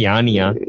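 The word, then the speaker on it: Yanni: ஐயோ நான் கரெக்டா ப்ரொனவுன்ஸ் பண்றேன்னு தெரியல எண்ணியா யானியா யானி யானி யானியா சரி யானியோட எந்த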